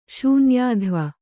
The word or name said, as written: ಶೂನ್ಯಾಧ್ವ